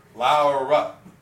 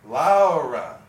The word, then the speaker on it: Laura: My name in English, Laura, is not uncommon in Spain. But of course, it is pronounced like it actually looks, Laura.